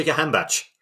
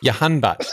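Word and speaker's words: Jahanbakhsh: But then, on the other hand, you bring in players like Jahan Kabash. I'm saying that wrong, aren't I? – Ali Reza Jahanbach. – Jahanbach, there you go.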